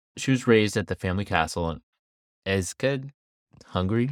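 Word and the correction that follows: Ecsed: How do you say that? I'm going to look it up so I don't sound like an asshole. So she was raised at the family castle in Eched, and in 1575, she married Count Nadazdi.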